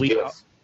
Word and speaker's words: GIF: But that was GIF. – I pronounce it GIF like the peanut butter. I've been told both ways.